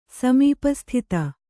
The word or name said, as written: ಸಮೀಪಸ್ಥಿತ